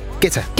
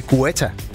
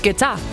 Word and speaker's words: Guetta: Dengang jeg arbejdede på Bruxelles-radio, der sendte han engang en fax om, at hans navn udtales Guetta. Og ikke Guetta, som alle os andre havde grund at kalde ham. – Men det er fransk, så er det Guetta.